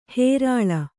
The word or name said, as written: ಹೇರಾಳ